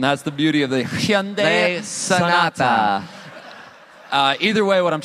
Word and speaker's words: Hyundai: Excellent pronunciation of Hyundai. – Little, yeah, sorry, what was that? – I was saying you pronounced Hyundai very correctly. Yeah, Hyundai. – A lot of people just say Hyundai.